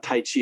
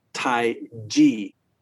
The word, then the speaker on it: tai chi: So you get things like tai chi, which is, and by the way, the word tai chi is actually pronounced not tai chi, it's tai chi, more like ji, which means the great ultimate.